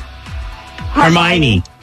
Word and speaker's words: Hermione: Is it Harmony? Because I don't... – Hermione. Yeah.